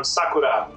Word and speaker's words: Sakura: I'm not a fluent Japanese speaker myself, although I am a weekly sushi eater at my favorite sushi restaurant, but it is pronounced Sakura or something close to that.